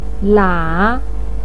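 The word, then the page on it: lia: tone 3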